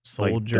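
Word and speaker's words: Souldiers: Soul being spelled like the word soul. Soldiers.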